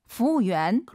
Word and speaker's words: 服务员: 服务员. – 그렇죠. 한 번 더. – 服务员. – 服务员. 이거 우를 빼고 한번 발음해 볼게요. 후엔, 후엔 빠르게 발음하시면 더 맛깔나는 중국어가 될 것 같습니다.